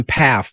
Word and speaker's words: Pfaff: Well, Jim Pfaff, by the way, I dropped the first F in pronunciation. Sorry. For those who are old enough to remember sewing machines, everyone knows about Singer sewing machines, but yep. The big one was the Pfaff sewing machine, P-F-A-F-F.